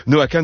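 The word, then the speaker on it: Noah Kahan: Noah Kahn, oui on dit Kahn, même si ça s'écrit Kahan. Noah Kahn donc a été cité 5 fois aux Grammy Awards en 2023, pour son album précédent, Stick Season.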